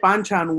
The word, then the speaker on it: banchan: So I can get banchan with my fried chicken. – Just because you said it correctly, I take back half the bad things I ever said about you.